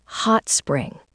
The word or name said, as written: hot spring